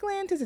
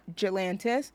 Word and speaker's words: Galantis: I think it's pronounced Galantis. It's definitely not Jalantis, but it definitely seems like an issue.